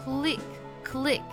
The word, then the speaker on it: clique: 好，这个单词读作 click，click。啊，我们来看这个句子。 That clique refuses to even talk to outsiders at their own lunch table。